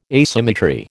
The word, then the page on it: asymmetry: American English